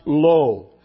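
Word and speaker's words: lo (לא): We saw that all of these negative commands, and there are eight, are framed with the absolute negative particle in the Hebrew pronounced lo, followed by the verb in the imperfect tense.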